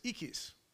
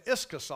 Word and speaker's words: ischus: The Greek pronunciation of the word strength is ikis. If you write it out in English, the English transliteration, it's pronounced I-S-C, it writes I-S-C-H-U-S. So I've been saying iscus all day.